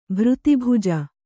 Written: ಭೃತಿಭುಜ

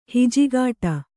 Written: ಹಿಜಿಗಾಟ

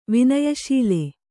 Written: ವಿನಯಶೀಲ

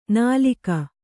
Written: ನಾಲಗೆ

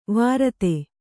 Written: ವಿರೋಧ

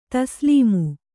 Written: ತಸ್ಲೀಮು

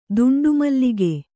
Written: ದುಂಡುಮಲ್ಲಿಗೆ

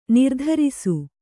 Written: ನಿರ್ದಾರಿಸು

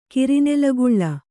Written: ಕಿರಿನೆಲಗುಳ್ಳ

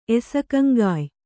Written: ಎಸಕಂಗಾಯ್

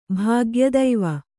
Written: ಭಾಗ್ಯದೈವ

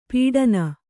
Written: ಪೀಡನ

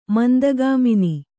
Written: ಮಂದಗಾಮಿನಿ

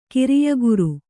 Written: ಕಾರ್ಯಾಗಾರ

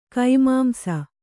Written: ಕೈಮಾಂಸ

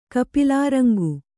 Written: ಕಪಿಲಾರಂಗು

